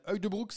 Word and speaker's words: Huydebroek: Du côté des satisfactions, bah, on a un autre Belge avec le souriant Kian Huydebroek, c'est comme ça qu'on dit.